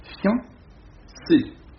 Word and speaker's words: fiancé: Prononciation et lecture, conversation et lecture, c'est fiancé, c'est-à-dire deux syllabes. Bon, il ne faut pas prononcer un i, mais on prononce un iode, fian, une seule syllabe, voyons.